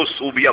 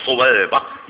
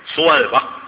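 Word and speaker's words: ثویبہ: ہم لوگ عقیدت میں نام تو رکھتے ہیں سویبہ, اس کو ہم نے اس کے تلفظ بدلے اس کو صوبیہ بنا دیا, یہ صوبیہ تلفظ غلط ہے, سویبہ یہ سے تھے, سویبہ سویبہ ابو لہب کی لونڈی.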